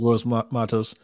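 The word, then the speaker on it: Gross-Matos: Like you said, their front 4 is good with Gross Matos. I don't know if I said the name right. – Yeah, you did Gross Matos.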